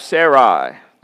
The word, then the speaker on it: Sarai: He took his wife, Sarai. Don't you like that little diphthong? You English teachers out there should be really proud of me, you know, because I usually wouldn't pronounce that Sarai.